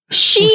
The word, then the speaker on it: Shein: It is pronounced Shein, right? – Shein. – Yeah. Not Shine. – No. – For the longest time, I called it Shein. – Sheen. Oh, Sheen. I think a lot of people call it Sheen as well. – S-H-E-I-N, right?